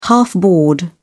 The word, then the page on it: half board: British English